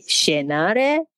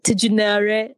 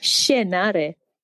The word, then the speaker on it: tjenare: And Shienare, which also means hi, is spelled T-J-E-N-A-R-E. Yeah. It looks weird. So you have the T and the J and you say Shienare. – Yeah. – Isn't it like Tijunare? No. It's Shienare. T-J-E-N-A-R-E.